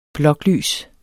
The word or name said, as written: bloklys